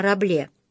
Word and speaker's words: Рабле: Хотя бы поэтому надо взять в руки и почитать роман Франсуа Рабле. Отсюда и «раблезианский». Учитывается непроизносимое «с» на конце, а заодно и то, что у французов, если это «с» попадает между двумя гласными, то произносится как «з» под названием «гаргантюа» и «пантагрюэль».